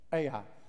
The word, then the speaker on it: Ai: Ai.